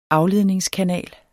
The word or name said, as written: AFLEDNINGSKANAL